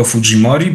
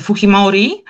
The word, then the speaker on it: Fujimori: No właśnie, postać również bardzo znana, Alberto Fujimori, bo to jest człowiek o pochodzeniu japońskim, natomiast ten wymowa nazwiska z hiszpańska raczej powinna chyba tutaj funkcjonować.